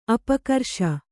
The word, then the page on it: ಅಪಕರ್ಷ: apakarṣa